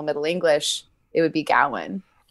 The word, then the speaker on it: Gawain: I think we should go with Gawain. – All right. – In deference to director David Lowery's choice.